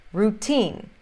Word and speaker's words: routine: Também quando a sílaba tônica começa com T. Routine, routine. S-T tem som de T mesmo, S-T verdadeiro. Alguns outros exemplos são hotel, hotel.